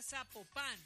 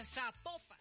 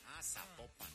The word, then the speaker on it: Zapopan: Oiga, nos escriben los oyentes y que no se pronuncia Zapopan, sino que se pronuncia Zapopan. – Zapopan.